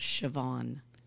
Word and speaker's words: Siobhan: S-I-O-B. – Yes. – H-A-N. – Yes, pronounced Siobhan with a V.